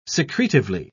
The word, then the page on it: secretively: British English